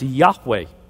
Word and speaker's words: Yahweh: The most common name is the name that William Tyndale came up with, Jehovah, which more recent Hebrew scholars tell us should be pronounced Yahweh.